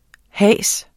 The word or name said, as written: hase